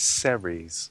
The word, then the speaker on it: Ceres: It's not serious. – Am I saying that wrong? – It's Ceres.